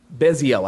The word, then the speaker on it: Bezalel: And he was the guy who, don't quote me on that, but Bezalel, I think is how you pronounce his name.